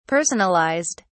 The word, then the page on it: personalised: British English